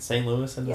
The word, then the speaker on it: St. Louisan: But yeah, it's a fun time to be a St. Louisan, right? St. Louisan, yes. Is that how you say it? – Sure.